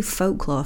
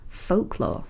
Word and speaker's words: folklore: Can I point out that you say folklore funny? – Folklore. Is it? How do you pronounce it? – You pronounce it like the Falkland War. – Folk. – It's folk. Folklore. – Folk. I can't say folklore, though. It feels weird. Folklore. – L is a silent L.